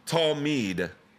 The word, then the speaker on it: talmid: So the first Hebrew word is the word talmid. Talmid. If you're taking notes, it is spelled T-A-L-M-I-D.